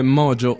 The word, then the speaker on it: Mojo: Si chiama esattamente come la trasmissione radiofonica, vale a dire Mojo Station, con la J in chiara cadenza italica, insomma, non per l'appunto come si pronuncia da queste parti.